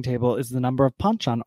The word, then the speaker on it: banchan: Banchan, am I saying that correctly? – Banchan, yes, banchan. Very good.